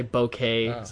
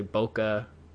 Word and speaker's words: bokeh: Although people argue over how you pronounce it. Some people say bokeh, some people say bokeh.